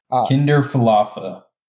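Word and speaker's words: Kiner-Falefa: On Saturday afternoon, they traded Mitch Garver, their starting catcher, for Isaiah Kinderfalala or something. I never even learned how to pronounce his name. – Kinderfalafa. – Falafa from the Rangers.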